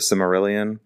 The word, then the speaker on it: Silmarillion: Want more uh, wish the the sort like the source material, the Silmarillion, I think is how it's mm-hmm. pronounced.